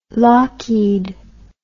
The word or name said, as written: lockheed